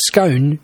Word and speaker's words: scone: Jane Mallion, 65, has explained that scone should be pronounced to rhyme with cone.